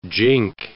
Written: jink

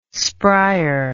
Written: sprier

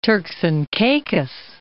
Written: Turks and Caicos

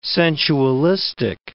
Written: sensualistic

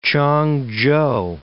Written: Ch'ang-chou

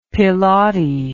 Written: piloti

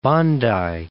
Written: Bondi

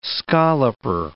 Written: scalloper

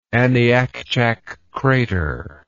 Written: Aniakchak Crater

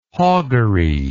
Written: hoggery

